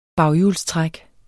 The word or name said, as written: baghjulstræk